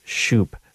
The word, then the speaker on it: Schouppe: So F.X. Shoup. Shoup, there it is. S-C-H-O-U-P-P-E.